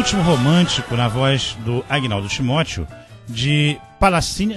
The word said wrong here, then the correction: Palatini: acho que é Palatini, né, que pronuncia italiano, Palatini e Donaggio.